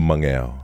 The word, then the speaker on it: Mageo: But you've got So in Samoan, the G has a silent N. – Okay. Before the G. – So it's Mageo.